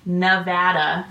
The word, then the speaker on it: Nevada: Also, so the listeners know, we live in Nevada, which is not Nevada. It is Nevada.